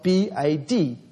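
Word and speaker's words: bad (בד): In Hebrew, it's spelled B A D, bad.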